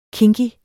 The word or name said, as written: kinky